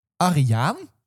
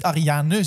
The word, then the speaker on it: Ariane: Willem-Alexander heeft gezegd dat je de naam van Ariane als volgt moet uitspreken. Ariaan. Dus je mag niet Ariane zeggen, maar het is Ariaan.